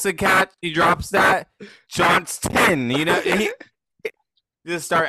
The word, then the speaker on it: Johnston: Got to pronounce that T. yeah. I know he hasn't been like. – It's a big distinction in my house, okay? – That's right. The Johnstons really versus personal. The Johnsons.